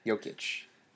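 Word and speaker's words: Jokić: Jokic.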